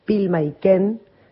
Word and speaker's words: Pilmaiquen: que es Pilma Iken, se escribe con Q-U-E-N, Mlicota.